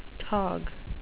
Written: tog

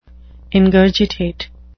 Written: ingurgitate

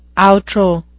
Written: outro

